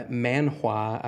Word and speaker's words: manhwa: So this is a uh, manhwa, I believe is how it's pronounced.